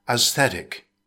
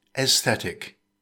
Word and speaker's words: aesthetic: It consists of three syllables. Stress the second syllable. In British English, it's pronounced aesthetic. In American English, it's often pronounced aesthetic.